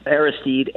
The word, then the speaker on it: Aristide: I didn't realize that you knew Jean-Baptiste Aristide, if I'm saying it right. – Yeah, Jean-Baptiste Aristide.